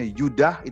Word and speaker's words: Yehuda: Dalam bahasa Indonesia kita ngomongnya Yehuda, E-nya kuat tapi sebenarnya dalam ejaan Ibrani itu E-nya juga lemah. Maka dibacanya Yehuda atau dibacanya Yudah itu malah benar.